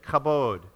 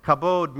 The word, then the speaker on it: kabod: You have to say it like you're going to spit, kabod. Okay? Kabod means glory.